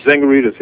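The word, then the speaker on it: Tsangarides: Chris Dzangaritis, how do you pronounce this? Tangaritis? – Tangaritis, yeah. Chris Tangaritis.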